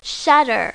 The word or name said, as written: shutter